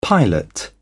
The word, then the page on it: pilot: British English